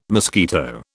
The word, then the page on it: mosquito: British English